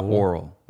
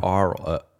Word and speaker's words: aural: It's not oral. No. Aural. – Like A-U. – Yes, A-U.